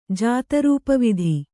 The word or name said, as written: ಜಾತರೂಪವಿಧಿ